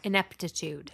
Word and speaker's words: ineptitude: It is also said that the high death toll was due to the cowardness and ineptitude of the crew. – Ineptitude. – Ineptitude. Thank you. Go. I wrote it right. I said it wrong.